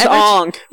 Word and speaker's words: Aang: It was Aang.